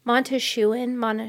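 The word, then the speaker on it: Mondoshawan: Montachuin. It's not pronounced the way it's spelled. – No, because in the subtitles it's SHA. Yeah. But it's Montachuin.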